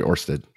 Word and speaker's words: Ørsted: So Exxon has just entered into 12-year agreements with, I think it's Ørsted. Is that how you pronounce it? – I think that's right, Ørsted.